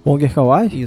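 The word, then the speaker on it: Wong Kar-wai: Monger Kawaii? Isso. Obrigado pela pronúncia.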